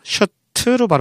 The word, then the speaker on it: shirt: 긴 면바지니까 롱 카키 팬츠고요 셔츠는 한 벌이니까 셔트로 발음하죠. 셔트. 셔트. 셔츠가 아니에요. 복수가 셔츠고 단수는 셔트다. 셔트.